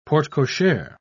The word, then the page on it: porte-cochere: British English